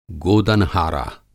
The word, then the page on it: गोदनहारा: Hindi